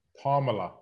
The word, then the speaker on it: Kamala: So Vice President Harris, this is I believe it's Kamala. – Kamala.